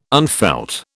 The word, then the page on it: unfelt: British English